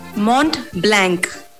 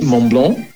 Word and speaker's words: Mont Blanc: Mont Blanc. – That should be Mont Blanc.